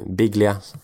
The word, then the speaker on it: Biglia: Sen Lucas Biglia, Biglia ska det uttalas.